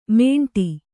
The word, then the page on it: ಮೇಂಟಿ: mēṇṭi